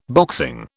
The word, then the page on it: boxing: British English